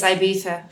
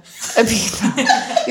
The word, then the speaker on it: Ibiza: Or as they say, what is the right way to say it? – Apparently it's Ibiza. – Ibiza.